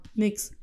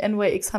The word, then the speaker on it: NYX: Nix. Nix wird übrigens nix ausgesprochen, nicht NYX, haben die jetzt auf Twitter gepostet.